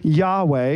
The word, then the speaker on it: Yahweh: So again, it shows up in our scripture as L-O-R-D. But the way this appears in Hebrew is Y-H-W-H. Sometimes we'll pronounce this as Yahweh.